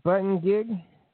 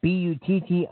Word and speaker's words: Buttigieg: Peter Buttigieg, if I pronounce it right, it's Buttigieg.